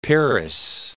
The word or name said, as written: peeress